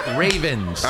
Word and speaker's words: Ravens: O los Ravens, ¿cómo se pronuncia? – Ravens. – Ah, los Ravens. – Los Ravens. Exacto. Los Ravens son las gafas. Ravens. There you go.